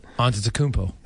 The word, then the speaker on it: Antetokounmpo: I'm not going to try and pronounce his last name, but Antetokounmpo.